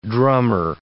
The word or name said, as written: drummer